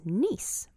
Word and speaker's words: Nice: And the city in the southeast of France is Nice, but it's spelled N-I-C-E. – Okay. – So it's Nice mice. Nice mice.